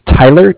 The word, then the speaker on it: Tyler: My name is Tyler, T-Y-L-E-R. And I'm calling with Cardiff. It's spelled C-A-R-D-I-F-F, as in Frank.